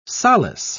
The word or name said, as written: solace